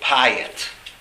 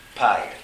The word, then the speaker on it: Piyut: And I remember growing up and going to shul with Rabbi Soloveitchik, that he loved Piyut. The way he pronounced it, he called it Piyut. Piyut. That must have to do with what part of Europe you're from.